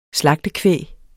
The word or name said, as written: slagtekvæg